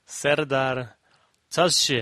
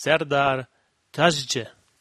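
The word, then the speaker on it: Serdar Taşçı: Da ging es um den Body Mass Index und noch einmal @santapauli1980 mit dem Tweet zur Aussprache von Serdar Taschi. Ich weiß nicht, ob ich da so korrekt bin, aber die korrekte Aussprache ist Serdar Tazce.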